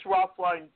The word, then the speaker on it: Roethlein: Uh, I have a poet page, uh, James Roethlein Poet, and grab a pencil if you want.